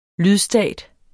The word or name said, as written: LYDSTATER